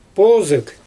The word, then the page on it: poozyG: Xyzyl